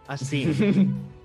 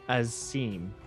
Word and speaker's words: Assem: My name is Asim. – Asik. – Asim. – Asim. Okay. – I believe it's spelled A-S-S-E-M. Asim.